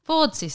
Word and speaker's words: Fordyce: And then you could get Fordyce? Fordyce? Well, how would you say that? Fordyce spots. – Don't know. That sounds right.